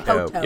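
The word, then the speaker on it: Poteau: Poto. – Yeah. It's spelled with an E-A-U on the end.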